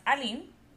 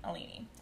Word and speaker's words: Aline: And my name in French is pronounced Aline, but being Brazilian, we pronounce Alini Alini. So when we moved to the U.S., my name became Alini.